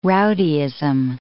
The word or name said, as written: rowdyism